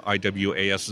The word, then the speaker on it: Iwasz: Uh, you know, my last name, for example, is spelled I W A S Z. It's pronounced Iwas.